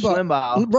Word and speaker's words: Limbaugh: How do you say his name? – I don't know. I don't know who you're talking about. – The one that President Trump just threw a medal around. – Oh, Limbaugh. Rush Limbaugh.